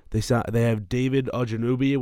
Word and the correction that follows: Ogunjobi: whatever. How do you say Ogunjobi. – His name? Ogunjobi. – Larry Ogunjobi.